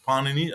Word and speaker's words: Panini: Panini, as the stress has to be on the first syllable, was an Indian linguist, most probably living in the 5th, maybe in even the 6th century before Common Era.